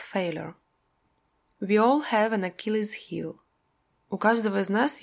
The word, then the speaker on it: Achilles: Единственное произношение «Achilles» немного своеобразно – «Achilles», а само выражение звучит «Achilles heel».